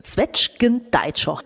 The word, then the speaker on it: Zwetschgendeitscher: Zwetschgendeitscher. Richtig ausgesprochen, Steffi? – Deitscher ist richtig ausgesprochen, ja.